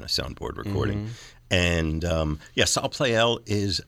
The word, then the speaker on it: Salle Pleyel: So do I pronounce this Salle Pleyel? – Salle Pleyel.